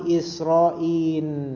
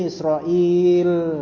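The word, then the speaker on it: Israil (إسرائيل): Ada juga yang mengatakan Israin. Lamnya diganti nun. Jadi itu banyak tuh macamnya. Macamnya banyak. Tapi antum bacanya Israil. Dengan ya diantara hamzah dan lam. Jangan diubah.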